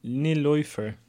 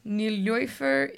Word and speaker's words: Nilüfer: Uh, it's spelled N U I no yeah, N I L U with the dots on it. – Omlaut. Omlaut. Yeah. – And then F E R. Nilif. – Nil Niloifer.